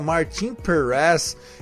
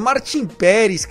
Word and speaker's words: Martin Pérez: Gostei dessa sua, dessa sua pronúncia, Martin Perez. É Martin Pérez, carai. Aqui é Brasil, porra.